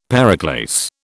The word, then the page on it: periclase: British English